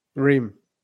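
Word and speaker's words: Reims: That's R E I M S. Go ahead, Charlie. How do I say it? Reim, Ream. Oh, it's just Reim.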